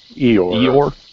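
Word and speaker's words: Eiroa: And for those who don't know, Asylum 13 is part of the CLE, which is Christian Eora. If I'm saying that correctly. But it's going to go ahead, I'm sorry. – No, I was going to... Christian Iora? – Eora. It's E-I-R-O-A. I think it's Eora.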